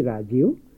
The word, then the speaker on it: রাজীব: রাজীব, তুমিও হিন্দিতেই বলেছ, তবে দেখো বাংলায় রাজীব হলেও হিন্দিতে রাজীব ব না ব। বাংলায় এই দুই বয়ের উচ্চারণ পার্থক্য না করলেও হিন্দিতে তা হবে না।